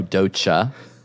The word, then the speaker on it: Doja: I feel like the way you pronounce Doja, it was like D-O-Z-H-A. – Oh, Doja. – Which is not how you pronounce her name, I don't think.